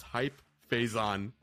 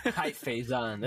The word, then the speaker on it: Faison: The character's name is Hype Faison. – Hype Faison. – But it's spelled F-A-Z-O-N, and his actual last name is F-A-I-S-O-N.